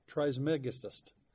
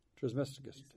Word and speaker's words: Trismegistus: Hermes Trismegistus. I'm going to spell it Trismegistus. Trismegistus.